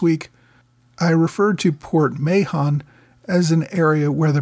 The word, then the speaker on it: Mahon: It should have been Mahon.